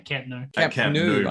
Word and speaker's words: Camp Nou: So 91,553 people attended the Barcelona and Real Madrid match last week at Camp Nou. Is that how you say it? – Camp Nou, breaking the previous record from the 1999 Women's World Cup.